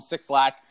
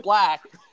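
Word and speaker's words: 6LACK: He's just chalked it up to you know, like, people are just gonna call him Six Lakh. – I thought I was calling him Six Black. – No, it's pronounced Black. But for some reason he put a 6 instead of a B.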